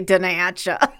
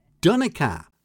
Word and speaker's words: Donnacha: So go on, try and pronounce the first name. Something, something O'Dooling. – I'm just going to go with the American pronunciation and say Denacha. – Do you have to be a Chicagoan? – Denacha. What do you say? – Donica. – That's not bad, actually. You're good at this. It's Donica.